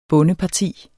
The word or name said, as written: bondeparti